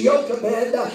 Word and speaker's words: Jochebed: It's King James, it's Jochebed, but it's pronounced Jochebed in the Hebrew.